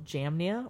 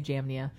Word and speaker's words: Jamnia: and that's just two different ways of pronouncing the same city name. – It's spelled Yavna. Like... – It's spelled Yavna, but we say Jamnia.